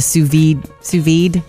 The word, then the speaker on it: sous vide: I made it on Memorial Day when we went to our friend's house, and they did the sous vide burgers. – Yes. – Said it right? – You did.